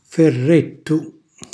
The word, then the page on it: ferrettu: calabrese